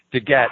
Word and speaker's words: DeGette: Is it Diane DeGette? – Diana DeGette. – It's a hard G. DeGette.